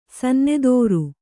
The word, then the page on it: ಸನ್ನೆದೋರು: Kannada